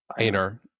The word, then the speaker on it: Anar: Anar, like a A.